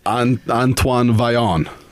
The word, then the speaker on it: Antoine Vaillant: Was that the Canuck? No, the French Canadian pronunciation? Was that the exact? – Antoine Vaillant, I believe. That's the only way I've ever known how to pronounce it.